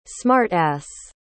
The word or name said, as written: smart-ass